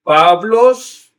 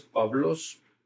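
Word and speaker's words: Pablos (Παῦλος): Entonces, dice, Paulos también se puede leer, pero es Pablos, porque la upsilon después de la alfa forma un diptongo que se puede pronunciar como B chica.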